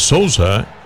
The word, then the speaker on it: Sousa: Un nuevo pitcher es Sousa. Por lo menos así lo pronunciamos en español.